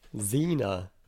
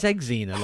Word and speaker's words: Xena: He's only been in Star Trek, Thor, The Boys, Lord of the Rings, Riddick, Xenia Warrior Princess. – Xenia. – Apart from that, Xenia Warrior Princess. How do you say it? – Xena. – Well, it's Xena, like Xavier Dolan.